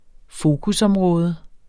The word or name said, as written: fokusområde